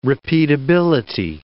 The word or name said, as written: repeatability